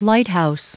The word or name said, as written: lighthouse